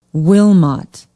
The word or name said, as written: wilmot